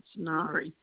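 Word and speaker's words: Ngaire: It's Nairi, N-G-A-I-R-E. I know. everybody else does. Oh, my God. Yeah, A-I-R-E. Yeah, so it's a silent G, so it's Nairi.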